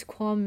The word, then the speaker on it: Quan: I don't know how to, Q-U-A-N, how do you pronounce that? – Q-U-A-N. Quan.